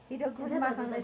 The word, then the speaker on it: Wiedergutmachung: They gave us the Wiedergutmachung, monthly pension. – Restitution. – That helped. They call it Wiedergutmachung. – Yes, they do. – Wiedergutmachung is spelled WIEDERMACHUNG.